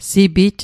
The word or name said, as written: sipit